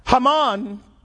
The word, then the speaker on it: Haman: Haman, which is the way you actually say his name, that brother is feeling real bad right now.